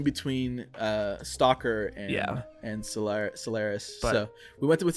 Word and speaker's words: Solaris: Next week, we're actually talking about Solaris? Solaris? I'm not sure how to pronounce it. – Solaris.